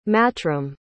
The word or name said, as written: matrim